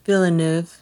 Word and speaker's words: Villeneuve: Villeneuve. – Villeneuve. – Okay. And I heard his name pronounced Denis. It's D-E-N-I-S.